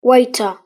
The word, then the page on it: waiter: British English